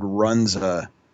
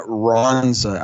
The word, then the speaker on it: Runza: Because you can't look at the word Runza and get Ronza out of it. I mean, it's literally pronounced how it looks.